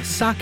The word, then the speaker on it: sake: Later in the show, sake at Provisions as they ready for their Japan Fest event. – Thank you for saying it properly.